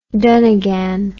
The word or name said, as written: dunnigan